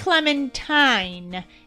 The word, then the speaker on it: clementine: It's a clementine. It rhymes with Valentine.